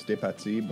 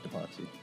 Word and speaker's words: DePazzi: It is a French name. It's DePazzi, but got you. – I'm an English fella, so there's... Butcher it a little, and yeah, DePazzi.